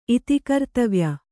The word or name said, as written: ಇತಿಕರ್ತವ್ಯ